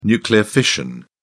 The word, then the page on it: nuclear fission: British English